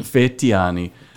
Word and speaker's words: Fetyani: Fetyani.